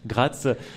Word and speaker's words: Graz: Graz.